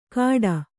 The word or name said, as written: ಕಾಡ